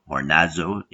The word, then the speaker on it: hornazo: And of course, everyone, families, students, young and old, will nourish themselves on ornazo, which is spelled H-O-R-N-A-Z-O, ornazo in English.